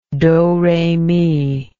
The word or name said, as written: doremi